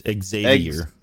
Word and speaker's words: Xavier: You're saying it wrong. – It's Xavier. – Xavier. – It may have now we're going back to the fucking TV. – It's like X-Z-A-Y-V-I-E-R. – Xavier.